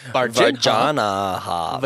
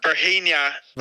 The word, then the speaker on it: Varginha: Is that really how you say it? Virginia? – Varginha. – Varginha. – No, shut up. Varginha. Shut up.